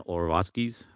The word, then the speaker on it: Orlovsky: And because you read them, and, and, sure, some of it could definitely seem like sarcasm, um, like Dan Orlovsky's.